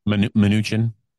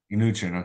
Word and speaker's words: Mnuchin: How do you say that name? Mnuchin? – Mnuchin. – Mnuchin. Okay, Mnuchin. Also told CNBC in March that he planned to assemble an investment group to bid for TikTok.